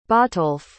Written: Botolf

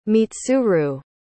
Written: mitsuru

